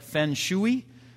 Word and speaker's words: feng shui: It's spelled F-E-N-G-S-H-U-I. So it's pronounced like feng shui. That's how Americans may pronounce it. But it's pronounced feng shui.